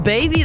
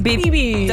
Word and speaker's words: Bieber: Du hast es auch nicht so Justin Baby, sondern auch so Justin Bieber. Das schreibt man B-E-B-I, das, was du gerade gesagt hast.